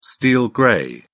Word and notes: steel grey: British English

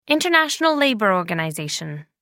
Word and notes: international labour organization: American English

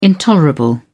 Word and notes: intolerable: British English

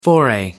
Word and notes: foray: American English

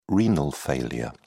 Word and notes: renal failure: British English

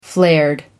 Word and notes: flared: American English

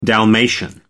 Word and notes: dalmatian: American English

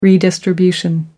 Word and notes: redistribution: American English

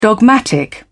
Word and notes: dogmatic: British English

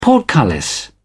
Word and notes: portcullis: British English

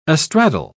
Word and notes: astraddle: British English

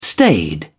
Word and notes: staid: British English

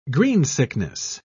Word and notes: greensickness: British English

